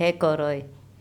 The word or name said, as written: ekoroy